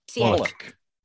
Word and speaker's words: Wallach: There's, of course, one other really major male part, which is Eli Wallace. Oh, Eli, brilliant. – Yeah, who is Arthur. Sorry. – Wallach. – Is it pronounced Wallach, is it? All right. – Wallach, yeah.